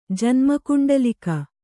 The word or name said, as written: ಜನ್ಮಕುಂಡಲಿಕ